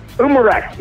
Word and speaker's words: Umarex: Is it Umarex? – Umarex, yeah, Umarex.